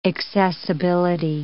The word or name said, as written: accessibility